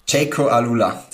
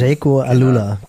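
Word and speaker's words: Jayco AlUla: Mir hast du es hier geschrieben, du bist gerade in der Höhe mit den Jungs von, wie werden sie ausgesprochen, das australische Team? – Jaco Alula, das World Tour Team. Jaco Alula, genau.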